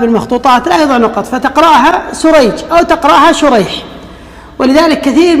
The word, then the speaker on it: سريج: في النطق، فيقال عقيل ويقال عقيل، أو بالعكس كأن تختلف الأسماء نطقا وتأتلف خطا، وتتفق الآباء خطا ونطقا، كشريح بن النعمان وسريج بن النعمان، الأول بالشين المعجمة والحاء المهملة، وهو تابعي يروي عن النبي عن علي رضي الله عنه، والثاني بالسين المهملة والجيم، وهو من شيوخ البخاري، فهو النوع الذي يقال له المتشابه.